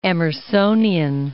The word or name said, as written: Emersonian